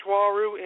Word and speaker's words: Uaru: And uh, for those of you who are wondering, if you want to do research on Walrus, Walrus is spelled U A R U, and it's actually pronounced Walru as in W A A R O W, Walru.